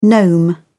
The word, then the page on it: gnome: British English